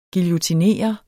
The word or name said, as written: guillotinere